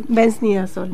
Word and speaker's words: benznidazol: La droga es benznidazol, ¿puede ser? – Sí, benznidazol.